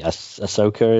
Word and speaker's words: Ahsoka: And then you've got the Ahsoka, is that how you say her name? – Yeah.